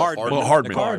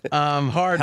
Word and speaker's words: Hardman: Hanman? Is that how you say his name? – McCall Hardman. Hardman. – Oh, I looked. That's an R. My writing could use some improvement. Hardman.